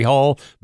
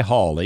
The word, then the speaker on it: Hall: My Facebook page, Carrie Hall, very simple, C-A-R-Y Hall, H-A-L-L.